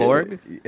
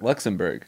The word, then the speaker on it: Luxembourg: You're saying Luxembourg wrong too. – Borg. – It's Berg. Luxembourg. – Yeah. – I'm pronouncing it B-O-U-R-G.